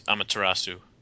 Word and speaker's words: Amaterasu: I guess, is that how you pronounce it, Amaterasu? – Yeah, I think it's Amaterasu.